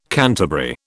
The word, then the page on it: canterbury: British English